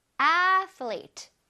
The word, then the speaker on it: athlete: Athlete. 뭐라고요? Athlete. Athlete. Athlete, athlete. 아틀리트가 아니라 athlete 치니까 혓바닥을 내밀었다가 슉 넣어줘야겠죠. Athlete, athlete.